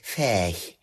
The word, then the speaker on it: Ferch: Da fährt man an Ferch vorbei, da geht, guck mal hier, Heino Ferch hier, guck mal, da wohnt Heino Ferch, oder man kann es auch sehr gut norddeutsch aussprechen, Ferch.